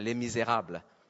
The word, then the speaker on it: Les Misérables: Because I only say it the French way, Les Misérables.